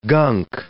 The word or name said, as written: gunk